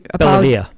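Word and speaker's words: Bellavia: The next person to receive the Medal of Honor from Niagara County was Army Staff Sergeant David Bovalia. I'm not sure if I'm saying his name right, and I Belvia.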